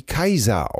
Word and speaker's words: Cäsar: Ja, ich fand äh, interessant einfach nur, danke für die Mail, dass äh, Cäsar äh, heute wie Kaiser ausgesprochen wird.